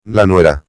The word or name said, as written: la nuera